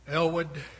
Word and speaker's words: Aylward: It's spelled A-Y-L-W-A-R-D, Elwood, because the British drop off consonants at times.